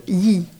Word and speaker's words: Yi: Et comment ce projet, pourquoi vous utilisez le nom de Yi? – Alors, peut-être que je prononce mal d'ailleurs. – Non, très très bien. I, ça se écrit Y-I, ça se prononce I.